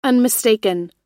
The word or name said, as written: unmistaken